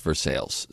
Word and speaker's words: Versailles: Although theirs is pronounced Versailles, so it doesn't matter, but still.